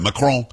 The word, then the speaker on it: Macron: You have to say it like that, Macron.